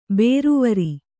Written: ಬೇರ್ವರೆ

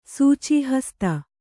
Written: ಸೂಚೀಹಸ್ತ

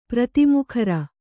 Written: ಪ್ರತಿಮುಖರ

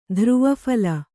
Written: ಧ್ರುವಫಲ

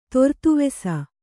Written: ತೊರ್ತುವೆಸ

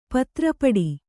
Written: ಪತ್ರಪುಟ

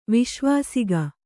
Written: ವಿಶ್ವಾಸಿಗ

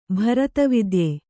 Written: ಭರತವಿದ್ಯೆ

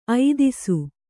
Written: ಐದಿಸು

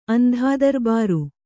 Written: ಅಂದಾದರ್ಬಾರು

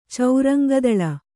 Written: ಚೌರಂಗದಳ

